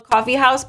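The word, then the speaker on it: Coffee Haus: It's called Coffee House, but it's spelled H-A-U-S, not the traditional house. – Oh, so, yes. – House. German.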